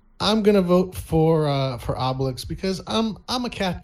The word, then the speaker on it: Obelix: It's gonna be oblique. Is that how you say it, Christy? Oblique? – Obelix. – Obelix. – Obelix. – Obelix. It's French, goddamn it. I don't know French.